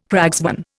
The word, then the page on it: cragsman: Indian